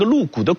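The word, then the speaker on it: 陆贾: 当时有一个儒生名叫陆谷，这个陆谷的谷啊就是贾宝玉的贾，读作谷。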